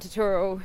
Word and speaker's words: Turturro: And I love John Turturro.